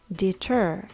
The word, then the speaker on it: deter: bitter と deter の違いは 2 つの音節のうちどちらにアクセントがあるかです bitter にはアクセントが最初の音節にあります deter にはアクセントが後の音節にありますアクセントの位置によってアルファベットの t の音が変化します。